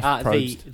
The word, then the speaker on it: Probst: Jeff Probst.